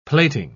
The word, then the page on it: plating: British English